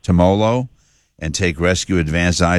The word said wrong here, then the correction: Timolol: I don't even know if I'm pronouncing it right. Can he get off? – Did I do a good job? – Timolol, but Timolol is a beta blocker.